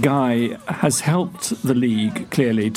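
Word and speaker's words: GAI: It was someone called Guy, which is spelt G-A-I, a rapper whose early songs about drugs and violence once were deemed unfit for public airing.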